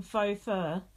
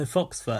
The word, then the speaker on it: faux: Before that, we had Chanel mispronouncing faux fur. – Oh, fox fur.